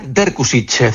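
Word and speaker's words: Drkušić: La quinta incorporación para esta temporada que se pronuncia Vanja Derkusić,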